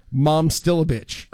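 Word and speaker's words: Momčilović: The player's name is Milan Momcilovic. And he said, remember it as Mom Still a Bitch. – Oh. – And if you say to yourself, Mom Still a Bitch, you'll never say it wrong ever again.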